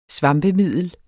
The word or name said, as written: svampemiddel